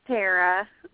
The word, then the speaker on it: Tara: I meant to ask you, is it Tara or Tara? – It's Tara.